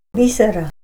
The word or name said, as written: b'isara